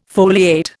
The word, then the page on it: foliate: Indian